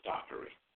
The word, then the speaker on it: Dockery: Dockery is spelled D-O-C-K-E-R-Y. Very similar to Hickory. Hickory, right? But we're saying Dockery.